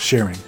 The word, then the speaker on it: Sheren: It's not spelled Sharon, it's spelled like my mother's name, S-H-E-R-E-N, Sharon, but it's sharing.